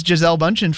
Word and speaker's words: Gisele Bündchen: What country is Gisele Bündchen from? That is how you pronounce her name, right? – Uh-huh.